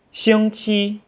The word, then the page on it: xingqi: xing qi